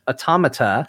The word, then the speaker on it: Automata: I'm going to just say it. It's pronounced automata.